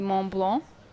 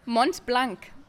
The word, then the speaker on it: Mont Blanc: I don't know if it's a German brand, but yeah, so we'd be split between pronouncing it French, which the best to my ability would be Mont Blanc. Sounds terrible. – That sounded beautiful. And how would you say it in German? – Mont Blanc. – Mont Blanc. – Yeah, that doesn't sound right.